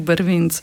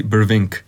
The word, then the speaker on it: Brvinc: Zdaj pa, jaz se bom samo še za trenutek vrnila k imenu Brvinc. Kako so ga, pa izgovarjali Američani? A so napačno? – Ga? – Ja, so ga, ampak mislim, da kako je bilo, vem, na koncu so govorili Brvink.